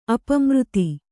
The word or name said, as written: ಅಪಮೃತಿ